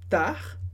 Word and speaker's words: tard: Eles se levantaram tard, que é tarde, sem pronunciar o D.